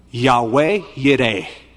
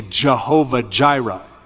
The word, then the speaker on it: Yahweh Yireh: And Abraham called the name of the place Yahweh Yireh, or as we often say, Jehovah Jireh, as we mispronounce those words, "The Lord will provide."